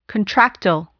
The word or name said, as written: contractile